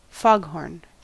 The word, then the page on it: foghorn: American English